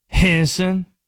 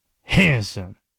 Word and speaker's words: Henderson: You want to know how the people in Henderson pronounce it? Henson. two syllables. Hanson.